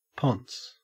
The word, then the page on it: ponce: Australian English